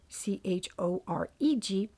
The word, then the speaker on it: choreg: It's spelled either C H O R A G or C H O R E G, choreg.